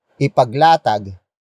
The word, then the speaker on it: ipaglatag: Ipaglatag. Ipaglatag. Ipaglatag is spelled as I P A G L A, T, A, G.